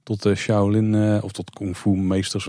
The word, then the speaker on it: Shaolin: Ehm, we zijn uiteindelijk ook in Shaolin terechtgekomen. Dus ik weet niet of het echt zo heet. Spreek ik nog enigszins goed uit? Dat spreek je heel goed uit, oh.